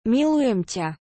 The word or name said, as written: milujem ta